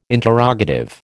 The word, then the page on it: interrogative: American English